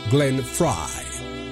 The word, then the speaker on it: Glenn Frey: El apellido se escribe Frey. Luego cualquiera pronuncia Glenn Frey. En un principio yo lo hacía así, hasta que cuando tuve el privilegio de hacer la versión en español del American Top 40, escuché a Shadow Stevens, el conductor oficial, pronunciando Glenn Frey. Desde entonces digo Glenn Frey, porque ¿quién soy yo para dar la contra a Shadow, verdad? – All right.